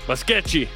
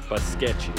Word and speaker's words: Basquete: Q-U-E-T-E is pronounced chi. – Yeah. – Basquete. – Yeah. T-E is chi. Yeah. – Yeah, I need some Portuguese lessons. And then Q-U-E is, K. Basquete.